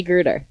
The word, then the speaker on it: Grueter: And Kiki Grueter.